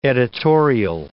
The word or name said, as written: editorial